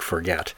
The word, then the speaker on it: Forget: I know that's spelled like forget, F-O-R-G-E-T, but it's actually pronounced Forget, by the way.